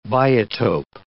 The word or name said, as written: biotope